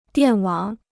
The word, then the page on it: 电网: diàn wǎng